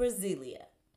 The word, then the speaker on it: Brasilia: Brasilia. So it's spelled B-R-A-S-I-L-I-A.